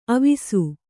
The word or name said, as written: ಅವಸೆ